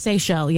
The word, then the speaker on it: Seychelles: Is it pronounced Seychelles? – Seychelles, yeah. – The Seychelles, islands. – S-E-Y-C-H-E-L-L-E.